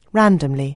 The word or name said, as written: randomly